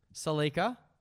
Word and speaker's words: Celica: You're pronouncing it as a K instead of a C. Celica. Delica.